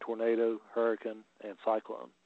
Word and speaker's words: Hurricane: Is that how you pronounce it? – It's yeah, Hurricane. That's the name. Hurricane. – Now, yeah. if you go in and call it hurricane, they will bitch and correct you.